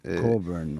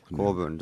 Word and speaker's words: Cockburn: Patrick Cockburn de bundan bahsediyor bugün. – Cockburn okunuyor. – Cockburn doğru. Hı. Özür dilerim.